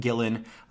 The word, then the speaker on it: Gillan: I just keep wanting to add extra syllables in this name because it's like Terry Gilliam and Gillian Anderson and right, yeah. – Gillian Jacob. It's just very confusing. But her name is Karen Gillan.